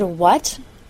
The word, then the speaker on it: what: The word what does not rhyme with the word that. What, W-H-A-T, rhymes with cut, C-U-T. And the word cut doesn't rhyme with put, P-U-T.